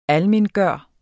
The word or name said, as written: ALMENGJORT